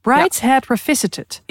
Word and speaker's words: Brideshead Revisited: Dus ik ben heel erg benieuwd naar de Brideshead Revisit... Ik moet het even goed kunnen uitspreken. – Revisited. – Brideshead Revisited.